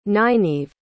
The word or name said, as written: Nyneve